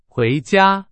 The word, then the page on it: 回家: Chinese